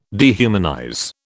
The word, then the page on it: dehumanize: British English